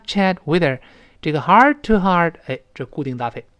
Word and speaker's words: heart-to-heart: 那读成 heart-to-heart，第一个 heart 后面那个 t 失去爆破，马上再读 to heart。